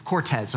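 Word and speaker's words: Cortés: Cortez is spelled with an S. Sometimes we white folk put a Z at the end, okay? But it's Cortez, okay?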